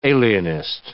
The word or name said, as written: alienist